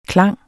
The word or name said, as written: klang